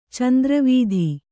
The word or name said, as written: ಚಂದ್ರವೀಧಿ